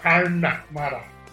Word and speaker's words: Unamara: Yeah, the emphasis on the R. Unamara. – Unamara.